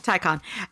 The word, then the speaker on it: Taycan: When I challenged the guy, you know, at the Porsche Taycan, I never say it right. – Taycan.